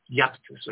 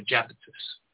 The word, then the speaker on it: Iapetus: The I in Latin is pronounced like a J. So it's Iapetus or Japetus.